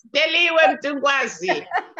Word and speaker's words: Deliwe Mtungwazi: Deliwe Mtungwazi.